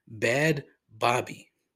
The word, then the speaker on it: Bhad Bhabie: Right behind the Catch Me Outside girl, who goes by the professional name Bad Bobby, but it's spelled B-H-A-D-B-H-A-B-I-E.